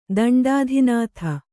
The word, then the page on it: ದಂಡಾಧಿನಾಥ: kannada